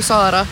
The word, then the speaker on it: Sara: Det är väl lite, jag heter ju Sara, så att finnarna säger ju Sara. Tekniskt sett är det samma namn, men inte känns det ju ändå riktigt som jag.